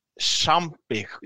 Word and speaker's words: Champi: Men Ellen Champi, jag vet inte hur man uttalar hennes efternamn. – Champi säger jag.